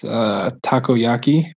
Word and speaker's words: takoyaki: I think specific to the Osaka area, it's takoyaki, right? Am I saying that right? – That's right.